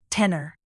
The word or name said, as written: tenor